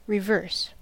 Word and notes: reverse: British English; /ɹɪˈvəːs/

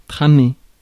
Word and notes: weave: American English; IPA: [wiːv]

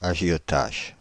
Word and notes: agiotages: /a.ʒjɔ.taʒ/

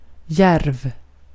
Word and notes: djärv: /jɛrv/

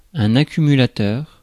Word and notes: accumulateur: France; [ɛ̃n‿a.ky.my.la.tœʁ]; French

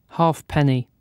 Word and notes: halfpenny: British English; /ˈheɪ.pə.ni/; /ˈheɪp.ni/

